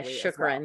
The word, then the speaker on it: shukran: And I always say chokran. I say it incorrectly, probably. – Yeah, shukran.